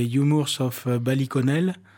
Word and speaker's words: Humours of Ballyconnell: Oui, le troisième, c'est Humours of Ballyconnell. Alors, ma prononciation n'est pas très bonne, peut-être que Martin va me corriger. – Non, c'est super.